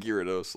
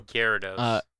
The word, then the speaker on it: Gyarados: I pronounce it Gyarados, like Gyro. – Gyarados. – I pronounce Gyarados.